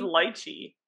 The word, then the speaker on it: lychee: I think it's lycha, or you know, lychee. – It was lychee. – Lychee, yes, okay, that's what it is. – I'm like, am I saying it wrong? – No, you're saying it right.